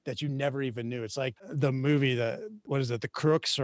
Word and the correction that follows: The Croods: or whatever I butchered that name, but where they're in the cave people. – Oh, the Croods. – The Croods, yes, the Croods.